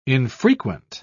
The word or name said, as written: infrequent